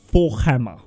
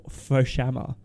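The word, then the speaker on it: Forchhammer: And I am going to attempt to pronounce the surname Forkhammer. Forkhammer. – Forkhammer. – F-O-R-C-H Hammer. – Forkhammer. – Forshammer, like Shamwell. Forshammer.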